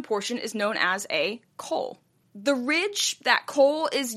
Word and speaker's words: col: A col, by the way, C-O-L is how it's spelled, col.